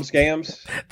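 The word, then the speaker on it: Wambsgans: Tom Wamsgams? – That's it.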